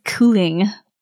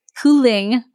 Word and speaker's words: kylling: Chicken in Danish is cooling. – Cooing? – Cooling. – Cooling? – It's spelled like K-Y-L-L-I-N-G.